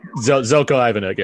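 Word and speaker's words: Željko Ivanek: Zelko Ivanek, yeah.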